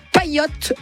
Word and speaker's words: Payote: Donc, Olivier Gély, donc il a créé avec succès Paillette, l'espadrille aimantée pour ramasser ses boules de pétanque. Donc, ça s'écrit P-A-Y-O-T-E.